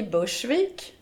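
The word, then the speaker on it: Burgsvik: Vi sitter alltså på Gotland, på södra Gotland, i Bursvik. Så uttalar ni det? – Jo. – Men det stavas Bursvik, om någon skulle vilja titta på kartan var vi är någonstans.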